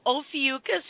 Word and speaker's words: Ophiuchus: And I go, Ophiuchus? And he goes, yeah, is that how you say it? And I'm like, yeah, you're kidding.